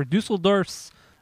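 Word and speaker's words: Düsseldorf: This brewing process is similar to that used for Dusseldorf's Altbier. Dusseldorf. Yes. And my German is very good, so yeah, I said that correctly.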